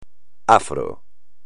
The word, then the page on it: afro: espagnol